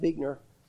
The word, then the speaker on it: Buechner: I saw this quote from Frederick Buechner, and it really is pronounced Buechner, even though it doesn't look like that.